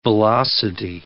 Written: velocity

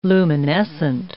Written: luminescent

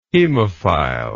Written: hemophile

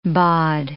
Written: bod

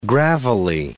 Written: gravelly

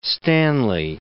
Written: Stanley

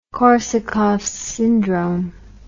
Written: korsakoffs syndrome

